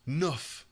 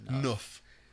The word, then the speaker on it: neuf: Nuff. In French, is how you say 9. Nuff. N-E-U-F.